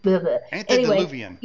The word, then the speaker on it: antediluvian: So if there was hybridization on this earth prior, you know, pre-Andalovian, I'm saying that wrong. It just, Antediluvian.